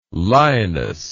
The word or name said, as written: linus